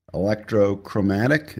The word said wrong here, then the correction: electrochromic: Is that what it is? – Uh, That's electrochromic. Right? – Electrochromic.